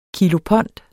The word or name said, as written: kilopond